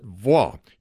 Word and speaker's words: waw: And in the Hebrew language, that word is vaw, spelled W-A-W. The first W is a soft V, so we pronounce it vaw.